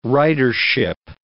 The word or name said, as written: ridership